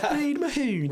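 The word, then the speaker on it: Reed Mahoney: Reid Mahoney. – Where you met Reid Mahoney.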